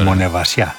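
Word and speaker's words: Μονεμβασιά: Τρεις μέρες στη Μονεβασιά. Προφορά καλή. – Πολύ ωραία. – Μονεβασιά.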